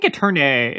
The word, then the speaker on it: Eternae: And I envision, okay, so they're called Eterni? – Sure. – Mm-hmm. – Which one is it? – I think Eternae is the more classical Latin pronunciation.